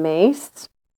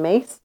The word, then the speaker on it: Myss: There's an author, her name is Carolyn Mace, and her last name is spelled M-Y-S-S, so Carolyn Mace.